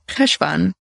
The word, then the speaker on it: Cheshvan: Welcome to the podcast for the month of Cheshvan. How did I pronounce that? – Perfectly.